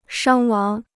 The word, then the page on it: 伤亡: shāng wáng